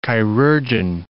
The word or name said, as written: chirurgeon